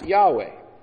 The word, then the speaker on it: Yahweh: That is what's on the front cover of the bulletin, and that we pronounce Yahweh.